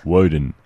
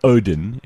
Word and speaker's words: Woden: Woden. Or Odin, if you prefer Odin, because they used to write it and pronounce it both ways.